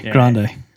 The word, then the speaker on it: Grande: Grande.